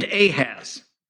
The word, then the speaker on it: Ahaz: The king of Judah is named Ahaz. At least that's how we pronounce it in English.